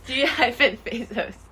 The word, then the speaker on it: Fazos: Why does that remind me of Jeff Bezos? – Oh, God. It rhymes. G-Fazos. – What's how you spell Fazos? – F-A-Z-O-S.